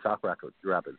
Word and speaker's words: Sauk Rapids: And this year it's between St. Cloud Icebreakers and Sartell Sauk Rapids. Did I pronounce that right? – Yep, Sartell and Sauk Rapids, yep.